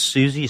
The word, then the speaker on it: Siouxsie: but it's Susie spelled like the town, Sue, S-I-O-X-S-I-E. I think I spelled that right. S-I-O-U-X-S-I-E.